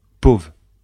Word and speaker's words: pauvre: Quand on le prononce, souvent, on ne prononce pas le R. Cela donne « pauvre ».